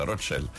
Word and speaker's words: Roccella: ho detto bene Mari, Roccella o Rocchella, Roccella,